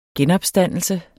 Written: genopstandelse

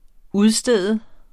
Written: UDSTEDTE

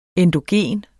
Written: endogen

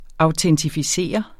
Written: AUTENTIFICERER

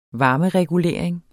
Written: varmeregulering

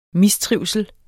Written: mistrivsel

